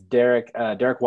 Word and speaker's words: Darrick: So my name is just spelled a little bit differently, but it's Darrick White, D-A-R-R-I-C-K, and then White like the color.